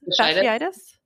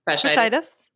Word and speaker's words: fasciitis: And how do you pronounce this? – Fasciitis. – Fasciitis.